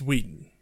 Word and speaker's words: Whedon: Thanks, Joss Whedon. And that was... God damn it, Joss. Don't pronounce his H. – Whedon? – It's Whedon. – Whedon. – Whedon. Joss Whedon.